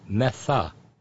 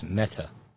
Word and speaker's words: Mehta: It's pronounced Metha. You seem to pronounce it as Meta. I didn't know what to do with the H.